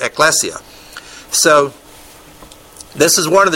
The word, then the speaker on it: ekklesia: The kahal of Israel is the congregation of Israel, and it's translated oftentimes through the Septuagint into the Hebrew word synagogue, but also ekklesia. Did I say that right? Or ekklesia, or whatever it is. My prisoner down there who is Spanish says it's ekklesia.